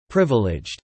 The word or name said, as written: privileged